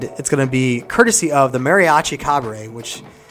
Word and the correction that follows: Cobre: C O B R E Cabre, right? Cabre, not yeah. Cobre.